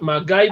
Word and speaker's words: MacGyver: MacGyver pues, este, se pronuncia MacGyver.